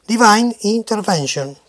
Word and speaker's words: Divine Intervention: Divine Intervention sería la pronunciación justa.